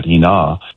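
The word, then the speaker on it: hina (ἵνα): And that's what that conjunction is, in order that hina is the Greek pronunciation there, in order that they will be destroyed.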